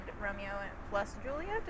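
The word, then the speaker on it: Romeo + Juliet: Which was Strictly Ballroom, Moulin Rouge, and Romeo plus Juliet is, I guess, how you say it. It is a plus sign.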